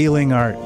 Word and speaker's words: Ealing: Ealing Art. As in healing art.